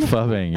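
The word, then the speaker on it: phubbing: Fubbing, yeah. We'll get to that. – Is that F-U-B-B-I-N-G? – It's a P-H-U-B-B-I-N-G. – Fubbing, okay. Phonetically a fub.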